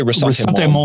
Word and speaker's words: ressentiment: Resentment, is that the right way of pronouncing that word? – I think they say ressentiment.